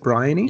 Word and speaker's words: Bryony: Bryony? – Yeah, that's how I said it in my head. Bryony.